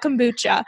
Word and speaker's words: kombucha: Well, this next one, this was directly from one of your posts and it's about kombucha. And am I saying that right? I don't even know. I don't drink the stuff. – People all say it all different ways. So yes, you're right.